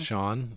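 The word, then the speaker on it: Schon: So Journey band members Jonathan Cain and Neil Schoen, is that how you say his name? – Sean.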